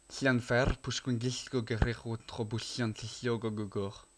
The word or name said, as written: Llanfairpwllgwyngyllgogerychwyrndrobwllllantysiliogogogoch